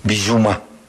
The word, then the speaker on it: Bissouma: Tiene razón, porque la O volante en francés se pronuncia U. Bissouma.